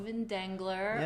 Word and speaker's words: Dengler: Yeah, Steven Dengler, yes. Hard G.